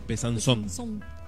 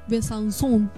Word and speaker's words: Besançon: Besançon. – Besançon.